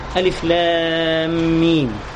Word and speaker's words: ألف لام ميم: بنقول ألف ما بنقولش آ، لأن الألف في غيرها من الكلمات بتبقى صوت بس، آ مش كده؟ فإحنا بننطقها باسمه، الحرف ينطق باسمه، اسمه ألف، فبنقول ألف ما بنقولش آ، ما بنقولش لا بنقول لام، ما بنقولش ما بنقول ميم، فهي حروف مقطعة.